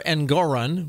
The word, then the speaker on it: Engoron: John Doherty, writing in Conservative Brief, says that a Northwestern University law school professor has sounded off following New York Judge Arthur Engoron, which rhymes with moron, his massive $355 million fraud finding against former President Trump, calling it the most unjust act in U.S.